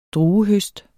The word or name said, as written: druehøst